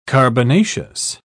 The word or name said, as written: carbonaceous